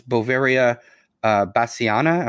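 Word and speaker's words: Beauveria bassiana: Now, let's talk about the main ingredient here, which is Boveria bassiana. Am I pronouncing that correctly? – That's absolutely correct.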